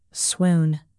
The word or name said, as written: swoon